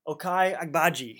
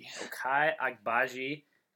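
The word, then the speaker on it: Ochai Agbaji: Okai Agbaji. – Okai Agbaji.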